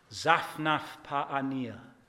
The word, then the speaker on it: Zaphnath-Paaneah: The name that Pharaoh gave him, now I've practiced this for years, Zaphnath-Paaneah. Now don't let anyone tell you that it's pronounced differently. That's exactly it.